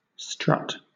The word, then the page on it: strut: British English